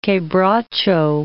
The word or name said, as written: quebracho